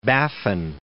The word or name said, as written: Baffin